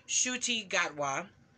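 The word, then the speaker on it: Ncuti Gatwa: I believe it is pronounced Shuti Gatwa.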